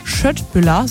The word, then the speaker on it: Köttbullar: Eine Sache, die bei IKEA ganz sicher nicht fehlen darf, eine schöne Portion Köttbullar nach dem Shoppen oder auch Schöttbüller, so wird es ja richtig ausgesprochen.